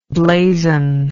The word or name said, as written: blazonment